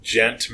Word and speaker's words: djent: I just figured out what gent is. – Gentleman? – No. I thought that too. I thought that was some new hipster crap, you know. – Gent is spelled D-J-E-N-T.